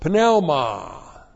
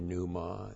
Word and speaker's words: pneuma: And then we have N plus the dative of pneuma. You say pneuma. No Greek would ever say pneuma. It's pneuma. There are no silent letters in the Greek. P-N-E-U-M-A. Pneuma, literally the Spirit.